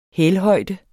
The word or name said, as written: hælhøjde